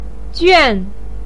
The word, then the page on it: juan: 4